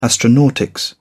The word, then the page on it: astronautics: British English